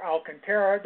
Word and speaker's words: Alcantara: But to move on from Urania, I want to talk about my favorite, Sandy Alcantara. Again, or Alcantara, depends on how you say it.